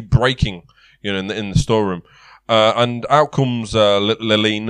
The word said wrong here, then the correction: Lenine: or Lerine or how you blooming pronounce that name of his. Lenine, that's it.